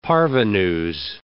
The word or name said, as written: parvenus